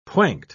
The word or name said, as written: pointe